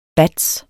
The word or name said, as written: batts®